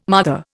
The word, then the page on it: mother: Indian